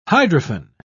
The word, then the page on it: hydrophane: British English